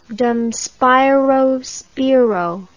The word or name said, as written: dum spiro spero